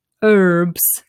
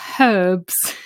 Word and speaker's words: herbs: In America, I think, tell me if I'm wrong, they say herbs. They don't pronounce the H, which we find very strange. We say herbs.